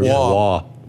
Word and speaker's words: Roy: Was his name Roy? – Or Wah. – Well, I don't. It's spelled Roy.